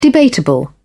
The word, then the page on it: debatable: British English